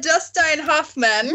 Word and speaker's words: Hoffman: Huffman. – C, Gene Hackman, or D... Robert Redford. – Oh my God! The answer would be a Dustin Hoffman.